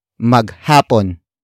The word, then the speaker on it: maghapon: And that is maghapon. Maghapon. Maghapon. Maghapon is spelled as M-A-G-H-A-P-O-N.